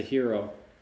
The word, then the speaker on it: gyro: Known as a hero. – A hero. That's right. Yeah. A hero. That's the more accurate pronunciation. But gyro is probably the more well-known.